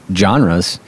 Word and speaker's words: genre: And I think it'd be, you know, a little plug here for the John Greening's class on biblical genres, because, did I say that right? – Genre. Give it a French lilt.